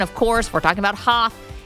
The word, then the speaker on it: Hoth: We're in the Hoth region.